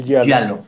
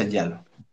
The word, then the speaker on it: giallo: El yalo, se pronuncia giallo.